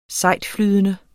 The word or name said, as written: sejtflydende